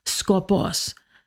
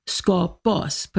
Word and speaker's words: skopos: This is one that Chrysostom often mentions by name, and that is skopos, S-K-O-P-O-S, skopos. Put the accent on the second syllable, skopos.